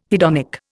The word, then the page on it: hedonic: Indian